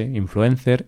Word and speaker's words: influencer: Así que eso, nosotros decimos influencer, influencer, mm, podemos pronunciar la C como una S, influencer, influencer, pero así lo decimos, no decimos nada raro, nada bien pronunciado.